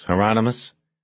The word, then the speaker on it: Hieronymus: He looked at Bosch through the camera and said, "Remember the promise, Hieronymus?" – "Rhymes with anonymous," Bosch said.